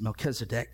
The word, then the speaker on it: Melchizedek: And so doing, Abram desired to pay a tenth of his spoils to the king of Salem, which was known as Melchizedek, or however you want to say it, but I pronounce it Melchizedek.